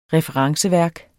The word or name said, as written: referenceværk